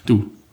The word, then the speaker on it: Doubs: Am Fluss Doubs, alle französischen Worte. – Doubs und alle französischen Worte lassen sich mit zwei Buchstaben aussprechen. – Du.